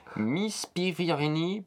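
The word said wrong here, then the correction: Miss Peregrine: Non, c'est Miss Pirigrine. – Miss Pirigrine.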